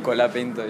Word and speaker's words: Colapinto: Colapinto, yeah, yeah Colapinto.